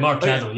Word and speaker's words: Mark Caddle: Mark Caddle, yes.